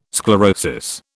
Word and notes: sclerosis: British English

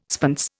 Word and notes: spence: Indian

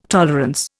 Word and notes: tolerance: Indian

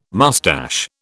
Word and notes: mustache: British English

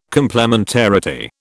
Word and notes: complementarity: British English

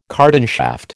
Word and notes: cardan shaft: American English

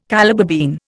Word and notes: Calabar bean: Indian

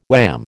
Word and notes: wham: American English

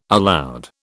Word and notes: aloud: British English

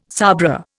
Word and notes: sabra: Indian

